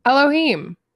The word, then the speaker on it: Elohim: No, it's E-L-O-H-I-M. – Elohim. – Elohim. Okay, thank you.